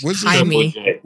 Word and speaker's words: Jaime: We're just referring to the nigga Jaime.